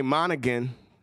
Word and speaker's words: Monaghan: She got a G in her name, so I'm going to say Monaghan.